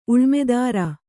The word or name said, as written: ಉಳಮೆದಾರ